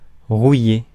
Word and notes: rouiller: France; [ʁu.je]

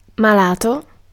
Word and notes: sore: /sɔː(ɹ)/; [sɔː(ɹ)]

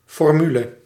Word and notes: formule: France; [fɔʁ.myl]

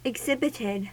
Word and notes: exhibited: /ɪɡˈzɪbɪtɪd/

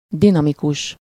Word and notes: dinamikus: /ˈdinɒmikuʃ/

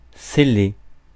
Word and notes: seller: France; [se.le]

